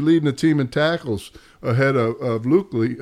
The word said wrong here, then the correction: Luke Kuechly: Luke Lee, or however you say his name. – Luke Kuechly, yeah.